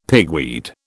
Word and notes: pigweed: British English

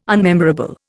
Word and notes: unmemorable: Indian

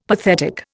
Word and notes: pathetic: Indian

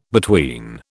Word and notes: between: British English

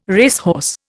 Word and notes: racehorse: Indian

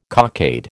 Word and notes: cockade: American English

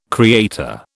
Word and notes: creator: British English

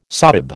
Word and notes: sahib: American English

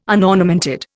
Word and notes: unornamented: Indian

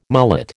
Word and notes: mullet: American English

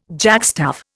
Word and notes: jackstaff: Indian

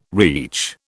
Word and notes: reach: British English

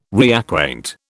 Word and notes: reacquaint: British English

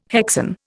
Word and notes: hexane: Indian

